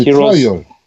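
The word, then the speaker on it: Trial: 뭐 아, 히어로스 트라이커? – 아니 트라이얼.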